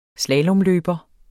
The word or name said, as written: slalomløber